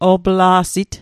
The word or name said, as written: opla'sit